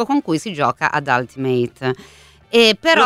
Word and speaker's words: Ultimate: Scritto Ultimate, lo pronunciamo Ultimate, è corretto? – Ultimate.